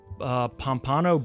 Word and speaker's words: Pompano: He's from Pompano Beach. He's facing Pompano. – A felony. Pompano. Okay, I knew I'm saying that wrong.